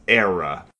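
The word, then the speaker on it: Éire: But Seamus McTyrion pronounces it, and it is... The way he pronounces it is era.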